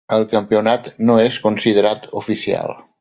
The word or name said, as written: considerat